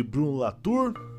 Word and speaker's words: Latour: que aqui no Brasil nós chamamos de Bruno Latour, né, mas tem a pronúncia em francês aí, faça biquinho.